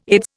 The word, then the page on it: Aids: Indian